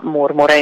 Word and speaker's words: Murmure: Murmure. In verità la pronuncia è in italiano, è alla latina,